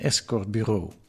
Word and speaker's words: escortbureau: Aanvankelijk als bardame in de club Bitterzoet, daarna als telefoniste bij een escortbureau. Escortbureau, zo spreek je dat eruit, ja.